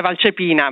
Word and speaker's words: Valcepina: Il cognome è pronunciato abbastanza bene, siamo da otto, l'accento è Valcepina, ma va benissimo così.